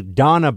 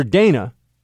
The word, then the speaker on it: Dana: Everyone calls her Dana because her name is spelled Dana. But you're supposed to know that her name is Donna. Now, you might be saying, Buck, if her name is D-O-N-N-A, of course, no, it's D-A-N-A.